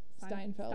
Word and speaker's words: Steinfeld: Like, I look at her and I look at Hailee Seinfeld. – Steinfeld. Steinfeld. – I, I always do that. – I forget I about it. her a lot of the time. Hailee Steinfeld. – Did I just say that right or wrong? Steinfeld. Stein. Okay.